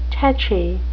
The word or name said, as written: tetchy